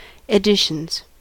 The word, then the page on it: editions: American English